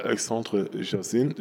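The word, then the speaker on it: Alexandre Yersin: Und einer, der dann diese Pestpandemie äh, auch entsprechend genutzt hat, war äh, Alexandre Jazin, Jazin, ja, nee, also, also wird, wird französisch ausgesprochen, der stammt aus der französischen Schweiz ähm,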